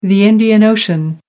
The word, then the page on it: Indian Ocean: American English